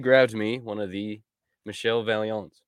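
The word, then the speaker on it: Michel Vaillant: You could also say Michael Valiant. – Michael Valiant. – Yeah, that's... – That's not Michael, though. That's Michel.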